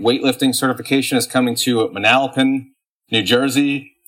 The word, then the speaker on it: Manalapan: I think I pronounced that one right. I pronounced it Manalapan on a video that I did going back to my old Indiana roots, and I got roasted for it on the internet. So Manalapan.